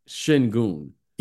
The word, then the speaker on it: Şengün: Şengün.